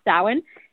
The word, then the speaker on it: Samhain: We do have some rain going on, so we're making some adjustments, but this particular Halloween, or as we call it, Samhain. It's spelled Samhain, but it's pronounced Samhain.